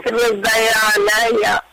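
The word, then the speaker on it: Hrudayalaya: Narayana Hridayala. Did I say that right? – Yes. – Cardiac Hospital. – Hridayala, yes.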